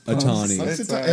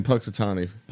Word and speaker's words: Punxsutawney: Puxitani. – Has an N in there? – Yeah, there's an I don't think it's pronounced. I think it's a silent N because I remember them saying Puxitani.